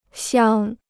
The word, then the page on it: 降: jiàng